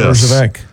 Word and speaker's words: Herjavec: Herjavec. – Yes. Herjavec.